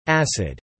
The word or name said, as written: acid